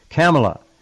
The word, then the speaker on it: Kamala: Kamala. That's how she pronounced her own name. Kamala.